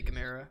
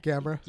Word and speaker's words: Gamera: I really love how you say Gamera. – Gamera? – Gamera. You say it like Chimera. Like that name better. – Gamera? No, Gamera sounds cooler.